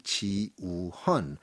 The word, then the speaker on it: Jiwooheon: Gallery Ji-woo-hun. And Ji-woo-hun is spelled J-I, that's Ji, W-O-O for U, and H-E-O-N for Hun. Once again, J-I-W-O-O-H-E-O-N in English, in case you want to do an online search.